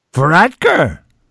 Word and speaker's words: Varadkar: Varadkar.